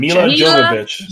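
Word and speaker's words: Milla Jovovich: The next character, Dakota Burns, played by, can't really, don't know how to say her name, Mia Jovovich. – Mila Jovovich.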